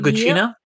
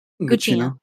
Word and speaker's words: Gugino: Oh, and Carla, how do you say Carla Gugino? – Gugino.